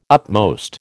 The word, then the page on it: upmost: American English